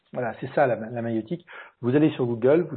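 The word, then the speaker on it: maïeutique: Alors la maïotique, c'est pas de la mayonnaise, c'est la maïotique en fait. Ça s'écrit M-A-I-T-I-Q-E, je crois.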